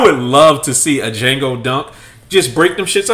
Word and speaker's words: Django: Um, a, a, Django Unk because the D is silent in both? Pretty much.